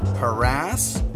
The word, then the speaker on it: harass: And even though the words are properly pronounced harass, Uranus, and nuclear, we're still putting the commute in commuter entertainment.